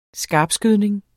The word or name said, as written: skarpskydning